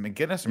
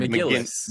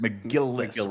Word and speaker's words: McGillis: How do you say her last name? Is it McGinnis or McGillis? – McGillis, right? They have absolutely zero chemistry.